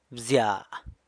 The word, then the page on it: bzya: San Lucas Quiaviní